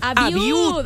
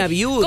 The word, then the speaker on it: Abiud: Bien, Abiud, Abiud. – Abiud, perdóname, Abiud. – ¿Cómo se escribe tu nombre? A-B-I-U-D, Abiud.